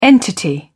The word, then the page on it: entity: British English